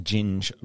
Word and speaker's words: ginge: Just as an FYI, Ginge rhymes with minge.